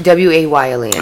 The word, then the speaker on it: Wayland: It was Wayland. Yeah, W A Y L A N D. – I got you. – Wayland, the way it's spelled.